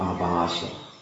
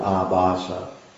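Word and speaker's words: ābhāsa: Then what is manifest is what Haridas Thakur, the Namacharya, calls Nam Abhasa. This word abhasa, long A, B-H, long A, S-A, nam, abhasa.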